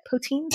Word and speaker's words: poutine: Poutine? – Poutine.